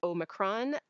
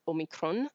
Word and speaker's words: Omicron: There are two what are sort of considered correct pronunciations. I pronounce it Omicron, which is definitely the sort of Canadian UK pronunciation that's trying to be as close to the Greek pronunciation, which would be, oh, I can't speak Greek, but it's more like Omicron.